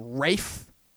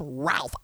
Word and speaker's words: Ralph: Like, you know, like the actor Ralph Fiennes, you know, the guy who plays Voldemort in the Harry Potter movies, you know, they pronounce his name Rafe. I'm like, dude, your name is not spelled Rafe. It's pronounced Ralph.